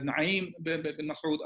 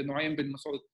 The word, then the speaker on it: Nu'aym bin Mas'ud: He came to Rasulullah sallallahu alaihi wasallam, and there are different pronunciation. Some of them refer to him as Na'im bin Mas'ud, others say Nu'aym bin Mas'ud.